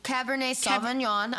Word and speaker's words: Cabernet Sauvignon: Cabernet Sauvignon. I took French class. Shout out you, Ms. Harmon. – Cabernet Sauvignon. – Yeah. Before today, I literally was like, Cabernet Sauvignon.